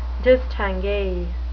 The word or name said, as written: distingue